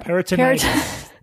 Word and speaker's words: peritonitis: Per- Peritonitis.